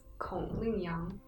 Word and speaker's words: Kong Ling Yang: No, it's Kong Ling Yang.